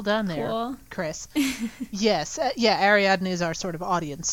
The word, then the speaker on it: Ariadne: I know what the character's name is, I'm worried about mispronouncing it, Ariadne.